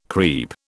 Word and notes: creep: British English

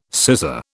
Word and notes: cesser: British English

